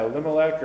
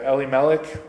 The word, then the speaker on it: Elimelech: Yeah, Elimelech or Elimelech, however you want to pronounce it.